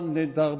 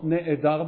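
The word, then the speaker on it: nedar (נאדר): Mi kamocha nedar bakodesh. Not ne'edar bakodesh, but nedar bakodesh. Under the aleph, there is no chataf, there is only a shva. So nedar, and we don't pronounce the aleph.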